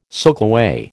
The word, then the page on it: soakaway: American English